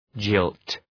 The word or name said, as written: jilt